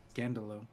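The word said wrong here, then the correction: escándalo: Or I'm, I'm sorry, I didn't say it correctly. It's escandalo.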